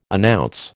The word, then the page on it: announce: American English